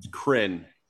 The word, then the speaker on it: Krynn: K-R-Y-N-N. – Yes. – Kryn.